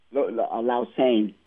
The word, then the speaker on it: Lausanne: I want to say, do you say it like Switzerland? Lausanne? – Lausanne. – Lausanne. – We say Lausanne. I'll say Lausanne in English so everybody will be like, oh. But if I go Lausanne, you know, people will be like, huh?